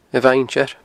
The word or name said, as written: Beinn Tir